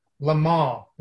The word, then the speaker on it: Le Mans: 24 hours of Le Mans or 24 hours of Le Mans? – Le Mans. If you're fancy, the French pronunciation means you don't really pronounce the S, so it is Le Mans. Le Mans. It's not Le Mans.